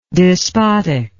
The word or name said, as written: despoticalness